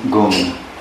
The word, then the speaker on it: Gung: And G for Gung. Gung. Sung. Sung. Gung. Gung. It is pronounced to the rhyme with the English word sung.